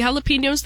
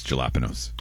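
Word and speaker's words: jalapeños: I picked like two or three jalapenos the other day for the very first harvest, but it's not enough. It's not quite enough to pickle yet. – I think you said it wrong. It's jalapenos.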